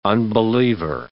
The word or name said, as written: unbeliever